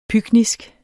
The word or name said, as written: pyknisk